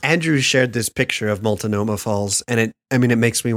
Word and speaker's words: Multnomah: It's pronounced Multnomah Falls.